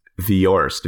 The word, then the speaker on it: Viorst: I think it's pronounced Viorst. V-I-O-R-S-T.